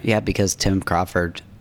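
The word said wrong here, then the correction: Commerford: You mean Comerford? – I don't know how to say his name.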